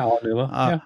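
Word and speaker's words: Aonuma: Anuma. – Yeah, Anuma.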